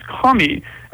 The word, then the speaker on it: kami: And, I get one of the things you deal with a lot is the concept, I don't know if I'm saying it right, but the kami? – K-A-M-I? Yeah. – And what are K-A-M-I. – These? – K-A-M-I, yeah. – How was it said? – Kami, that's right.